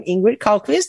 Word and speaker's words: Ingrid Carlqvist: Just search for my name, Ingrid Carlqvist, as you rightly pronounce it.